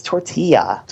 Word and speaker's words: tortilla: It's tortilla. – Tortilla, but there's no Y in it. – No, but the L's, two L's together make a Y.